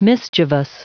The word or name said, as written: mischievous